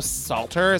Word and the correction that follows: Salta: is that how you say his name? – Salta. It's S-A-L-T-A.